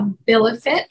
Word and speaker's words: AbiliFit: Her growing movement is called a Abilifit, and if I didn't pronounce that right, that's right.